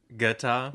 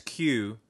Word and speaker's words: Qatar: Guitar? – Yeah. Qatar, but you have an accent, so yours sounds like, to me, it sounds like you're saying guitar. – Well, I hope I'm saying it right.